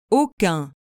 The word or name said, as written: aucun